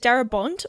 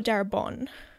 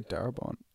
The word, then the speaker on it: Darabont: Is it Darabont or Darabon? – I'd say Darabont. – Darabon. – I would go with Darabon. – Okay. – That's how I read it in my mind when I was like reading about it before. – Silent T. Yep.